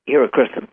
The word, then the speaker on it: Helichrysum: Is it hypersinium or? – Hierocrystin. – Right. It's hard to pronounce. I can spell it. – H-E-L-I-C-H-R-Y-S-U-M.